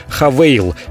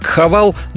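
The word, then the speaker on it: Haval: В кризис они запускают у нас продажи новой марки «Хавейл». Пишется как «Хавал», но они настаивают на произношении как «Хавейл».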